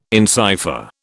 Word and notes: encipher: British English

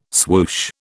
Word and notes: swoosh: British English

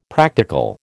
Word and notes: practical: American English

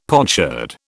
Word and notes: potsherd: British English

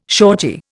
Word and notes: shorty: Indian

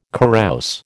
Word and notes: carouse: American English

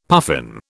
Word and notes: puffin: British English

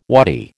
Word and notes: waddy: American English

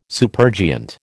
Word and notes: supergiant: American English

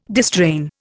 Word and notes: distrain: Indian

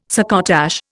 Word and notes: succotash: Indian

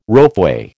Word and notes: ropeway: American English

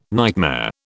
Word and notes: nightmare: British English

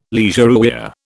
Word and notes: leisurewear: British English

